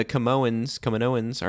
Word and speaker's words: Kaminoans: Kaminoans? Am I saying it right? – Kaminoans. Kaminoans. You literally never say it right.